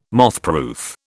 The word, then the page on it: mothproof: British English